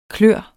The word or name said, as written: klør